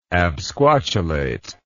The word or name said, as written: absquatulate